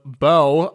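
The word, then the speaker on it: Børge: Ja, eure Königin heißt auch, immer wenn Sören, mein Kumpel von der Königin Makrele spricht, Name meines Großvaters wird B-O-R-G-E geschrieben. – Borge? – Und wird Bow ausgesprochen.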